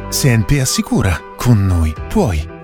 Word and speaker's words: CNP: Si legge Senpe, si scrive CNP.